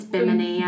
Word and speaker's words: Bimini: Bimini? – It's Bimini, Bimini Road.